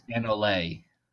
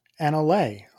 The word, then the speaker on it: Anole: I believe it's Anole. – Anole. Okay, that sounds prettier. I like Anole. – Well, I mean, here's the thing. It's a lizard. It's a real thing. So there is an appropriate pronunciation of this.